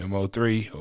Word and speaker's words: Mo3: M-O-3, how you pronounce his name? M-O-3 or is it Mo? Or is it Mo-3? – R-I-P.